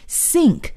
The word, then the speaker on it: sink: 那么这个水槽还有洗碗槽我们就可以叫 sink, S-I-N-K, sink。注意这里呢是 S 的发音，要发 S 的音，也就是这个舌尖不用伸出来，一起再来试一下 sink, sink。